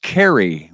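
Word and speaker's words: Caray: We know Chip Carey, pronounced Carey, will be the new television play-by-play announcer for the St. Louis Cardinals, broadcasts on Bally Sports this year.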